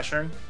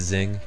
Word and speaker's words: Sheng: Yeah, Sheng. – I thought it was Zing. – Yeah, it's not Zing. That would be the English pronunciation.